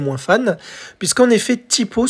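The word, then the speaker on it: T'Pau: Et donc Typo, le nom Typo, comment il s'écrit Alors ça s'écrit pas comme je le prononce. En fait, c'est T apostrophe PAU, ou comme euh, vous le voyez euh, en lancement du podcast, en le titre de l'épisode, vous voyez comment il est écrit.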